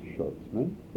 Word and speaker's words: Schutzman: Schutzman. – Would you spell it? – Absolutely. S-C-H-U-T-Z-M-A-N.